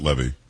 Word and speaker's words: Levy: By the way, I think it's Levy. I think Eugene says it Levy.